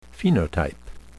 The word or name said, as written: phenotype